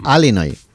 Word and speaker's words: Illinois: Se pronuncia Illinois. – Sí, perdón, sí. – Illinois. – Pues yo digo Illinois, si no entendemos todos. Porque como diga Illinois, nadie lo entiende.